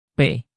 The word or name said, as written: p